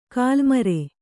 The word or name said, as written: ಗಲ್ಲುಮರ